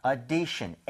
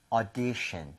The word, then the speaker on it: audition: 그래서 이거 발음하실 때도요 오디션이 아니라 5에서 조금만 더 벌려주시면 돼요. 그래서 audition, audition 조금만 벌려서 5보다는 차라리 어에 더 가까운 게 낫습니다.